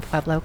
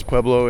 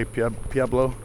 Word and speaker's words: Pueblo: So a lot of people pronounce Pueblo incorrectly. They say Pueblo.